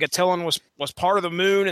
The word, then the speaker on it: Attilan: And I'm going to pronounce this as Atalan. I don't know if you guys are going. – No, it's Attilan.